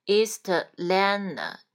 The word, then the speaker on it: Atlanta: T A N T 组合，A N T 的 T 可以不发音，那就是 Atlanta。对吧？然后再连读 East Atlanta, T 也是不发音 East Atlanta, na na na.